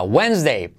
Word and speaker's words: Wednesday: Siguiente palabra que debes de saltar, la D, no hay que pronunciarla. Miércoles. Wednesday. Wednesday. ¿Dónde está la D? En ningún lado. Wednesday.